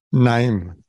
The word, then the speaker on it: Naim: Naim. – All right. Sorry about that. – No worries. – All right, so Ken, give us an introduction. – My name is Ken Naim.